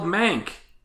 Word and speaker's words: Mank: But it's a movie called Mank, which is spelled like monk, but with an A.